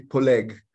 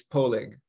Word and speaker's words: Poleg: Last name is actually Poleg. But okay. In North America, it's difficult to pronounce that. So it's Poleg.